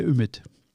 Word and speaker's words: Ümit: Ich würde gerne mal sagen, wie dein Name türkisch richtig ausgesprochen ist. Hi Ümit. – War das, das war richtig? schon sehr gut. Echt? – Ümit. – Ümit. Ümit. Ümit. Okay, kürzer. Okay.